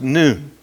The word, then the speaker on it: nun: In the English spelling, it's N-U-N, but it's pronounced noon. It's like our N.